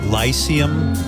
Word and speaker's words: Lyceum: Live from the, how do you say that? Lyceum? – Yes. – It's a London theater, the Lyceum.